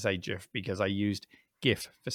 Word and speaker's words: GIF: Well, yeah, there really can be no argument because it was Stephen himself, the creator, who said it's a soft G. It's a GIF, and he said it multiple times, and he's made it very clear.